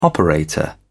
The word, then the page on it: operator: British English